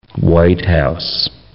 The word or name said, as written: White House